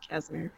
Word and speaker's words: Casimir: protected from space, and they've used the Casimir. Is that how you say it? Casimir? – Gate? Yeah, Casimir. Yeah.